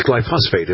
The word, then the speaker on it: glyphosate: It's glyphosate, isn't it? – Yes. – Isn't that what it is? I'm pretty sure. – Glyphosate. – Yeah, I'm pretty sure that's what it is. – Yeah, glyphosate.